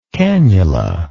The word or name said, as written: canula